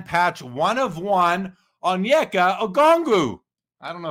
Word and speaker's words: Okongwu: Yeah, I, um, I don't Kong, like Okongwu. – Okongwu.